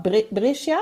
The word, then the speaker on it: Brescia: Brescia, jetzt richtig Genau.